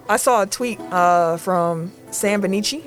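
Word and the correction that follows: Vecenie: If I pronounce his name wrong, someone please correct me. But say it again. – Vecini.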